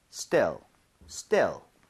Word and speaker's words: still: So still 이렇게 E 이러면 안 돼요. 얘는 입모양 벌리지 않은 상태로 편하게 갈게요. 두 번 갑니다. Still, still, still, still.